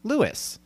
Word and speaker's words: Lewes: It's L-E-W-E-S. Delaware is pronounced Lewis. So I guessed. I took a shot. I was 50-50. I failed. That's why you never go with my bets. I pronounced it Lewes. He's claiming it's Lewis, Delaware.